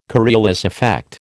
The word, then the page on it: Coriolis effect: American English